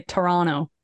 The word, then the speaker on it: Toronto: They were like, we know that you're a tourist because you say Toronto. You don't say Toronto.